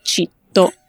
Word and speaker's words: citto: Solo una parola mi viene in mente che è ragazzo o ragazza, che noi non diciamo ragazzo, diciamo citto o città. Citto o città. Esatto, come città ma senza accento.